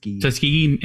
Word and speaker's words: Tuskegee: I think it's, you know, last week we did history on the airmen, the Tuskegee, how do you say it? Tuskegee Airmen? – Tuskegee Airmen, right? – Okay. Yep.